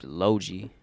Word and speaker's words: Loji: His name's Logi. – Logi. – I think that's how you say it. L-O-J-I.